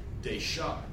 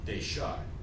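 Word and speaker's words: dejar: And in the varieties of Sephardic Spanish, this is pronounced deshar, with a sh, deshar. So again, we have a sh, front of the mouth, to a k type sound, back of the mouth.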